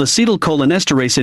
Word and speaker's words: acetylcholinesterase: See how chemicals called acetylcholinesterase inhibitors, ACHLs, might promote lucid dreaming.